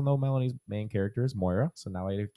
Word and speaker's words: Moira: Moria, I kept saying, or something like that. So he goes, it sounds like the word soy sauce. I'm like, soy sauce? What the fuck is this guy talking about? You know? And he's, think about it. He goes, Moy, soy.